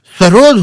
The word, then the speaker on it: Sarod: Sarod, actual pronunciation was Sarood.